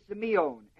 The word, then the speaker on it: semion: Now when it comes to the word for signs, S-I-G-N-S, the word is semion, S-E-M, long E, I-O-N.